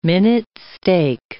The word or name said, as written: minute steak